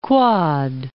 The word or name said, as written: Quad Cities